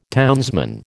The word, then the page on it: townsman: American English